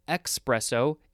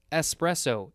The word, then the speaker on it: espresso: It's like the word espresso. The coffee espresso. It's not espresso, it's espresso, E-S-P, not E-X-P.